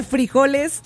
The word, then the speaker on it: frijoles: Está fuerte, o sea, trae frijoles, frijoles. Acento en la I. Frijoles.